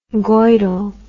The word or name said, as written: goidel